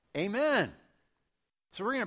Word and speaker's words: amen: Another that you may be less aware of is Jesus often in the Gospels will say, amen, amen. That, by the way, is how you pronounce it in Greek.